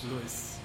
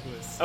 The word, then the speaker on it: Luis: Luis. – Luis? – Luis.